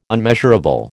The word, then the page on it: unmeasurable: American English